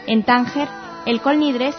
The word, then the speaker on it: Kol Nidre: Abrimos este espacio, como no podía ser de otra forma, con el Kol Nidre, tal vez la oración más emblemática del ritual judío. En ciertas comunidades se pronuncia Kol Nidre.